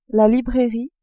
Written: librairie

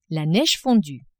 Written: neige fondue

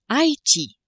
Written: Ile de Haiti

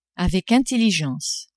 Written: avec intelligence

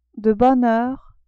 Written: de bonne heure

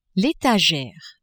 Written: etagere

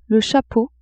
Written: chapeau

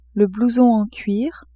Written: blouson en cuir

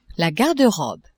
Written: garde-robe

